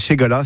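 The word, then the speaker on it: Segalar: C'est une ségaline, ségala, qui s'écrit en occitan avec un R à la fin, c'est un R muet.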